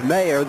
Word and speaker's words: Meyer: Her name is spelled M-E-Y-E-R, but it's pronounced M as if it was Mayer, the mayor of a town.